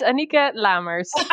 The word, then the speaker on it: Anieke Lamers: Annika Lamars, is that correct? – It's Annika Lamars.